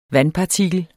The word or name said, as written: vandpartikel